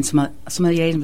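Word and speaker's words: sommelier: And then the wine sommeliers, was that saying that right? – Yeah.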